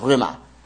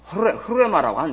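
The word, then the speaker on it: 흐르레마라고 (ῥῆμα): 정확한 발음은 흐르레마라고 하는데 흐르레마. 흐르 발음하면 흐르레마 이렇게 발음을 해야 되는데 어려우니까 그냥 레마 하지요.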